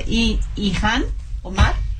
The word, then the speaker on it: Ilhan Omar: Ilhan Omar.